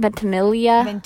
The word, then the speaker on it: Ventimiglia: They're just the actor that plays Jess, Milo Ventimiglia. How do you say his last name? Ventimiglia.